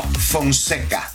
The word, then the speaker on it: Fonseca: All right, if you want to get connected, Julio Fonseca, am I saying it right? Francesca? – No, Fonseca.